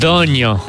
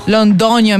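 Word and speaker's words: Londoño: A postat recent pe Instagram o poză din spital cu un băiețel foarte frumos și foarte sănătos pe numele Orion Londono, sper că am pronunțat corect. – Londonio. – Londonio, merci Gomez. Mulțumesc. – Gomez. – Deci pronunță-i tu numele, te rog. – Orion Londonio Gomez.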